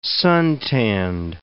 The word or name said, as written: suntanned